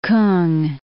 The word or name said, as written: K'ung